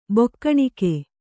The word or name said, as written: ಬೊಕ್ಕಣಿಕೆ